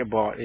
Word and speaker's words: Abbott: What is the proper pronunciation, ma'am? – Abbott. – Abbott. Okay.